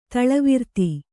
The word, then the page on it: ತೈಲವರ್ತಿ: taila varti